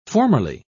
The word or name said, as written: formerly